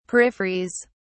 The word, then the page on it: peripheries: British English